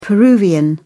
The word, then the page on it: peruvian: British English